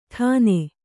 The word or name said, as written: ಟನ್